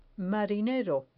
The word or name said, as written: marinero